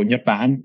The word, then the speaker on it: Nhật Bản: So Nhật Bản is how you say Japan in Vietnamese.